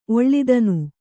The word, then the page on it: ಒಳ್ತನ: oḷtana